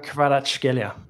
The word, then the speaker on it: Kvaratskhelia: And can you pronounce his name correctly for Nigel, who's probably watching the show right now? – Kvaratskhelia. – Very good. That's probably the best pronunciation here.